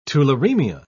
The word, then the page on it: tularaemia: British English